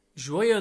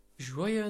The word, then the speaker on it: joyeux: Joyeux Noël. – Joyeux. – Joyeux. – Joyeux. – Joyeux. – Joyeux Noël. – So if you think of joyeux, it's spelled like J-O-Y, so like joy.